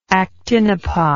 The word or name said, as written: actinopod